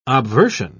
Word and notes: obversion: British English

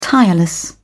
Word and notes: tireless: British English

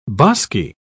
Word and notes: bosky: British English

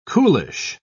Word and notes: coolish: British English

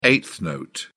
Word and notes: eighth note: British English

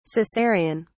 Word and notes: Cesarian: British English

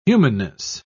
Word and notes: humanness: British English

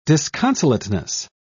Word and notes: disconsolateness: British English

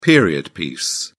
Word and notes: period piece: British English

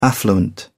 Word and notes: affluent: English; British English

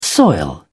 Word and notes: soil: British English

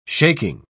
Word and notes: shaking: British English

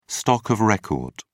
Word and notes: stock of record: British English

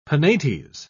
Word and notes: penates: British English

